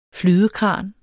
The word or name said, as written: flydekran